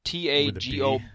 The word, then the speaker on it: Tagovailoa: And they had a graphic below him, and I think it said Tua Tagovailoa. So Tagovailoa. – Oh, jeez, yeah, terrible. And nobody pronounces it right.